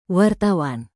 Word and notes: wartawan: Indonesian

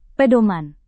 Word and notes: pedoman: Indonesian

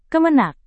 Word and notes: kemenag: indonesian

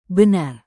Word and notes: benar: indonesian

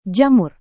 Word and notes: jamur: Indonesian

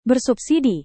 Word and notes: bersubsidi: indonesian

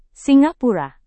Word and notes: singapura: Indonesian

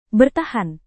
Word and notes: bertahan: indonesian

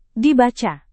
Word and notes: dibaca: Indonesian

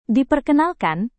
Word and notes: diperkenalkan: Indonesian

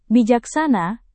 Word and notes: bijaksana: Indonesian

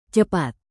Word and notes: cepat: indonesian

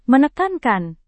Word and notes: menekankan: Indonesian

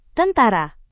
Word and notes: tentara: indonesian

